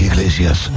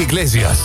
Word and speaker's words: Iglesias: Nicky Iglesias. – Ja, dat moet je zo uitspreken. Als je er een beetje vocht bij doet en consumptie, dan klinkt het al heel snel Spaans. Nicky Iglesias.